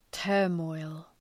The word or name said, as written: turmoil